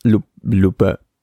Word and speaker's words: Lupin: creo que es Lupe, porque la N no se pronuncia.